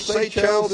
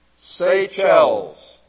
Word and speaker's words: Seychelles: You know where Seychelles is? Seychelles.